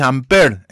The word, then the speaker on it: Ampère: se llama Ampere, que es Ampère, en realidad se pronuncia en francés, Ampère.